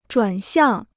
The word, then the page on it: 转向: zhuàn xiàng